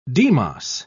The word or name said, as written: demos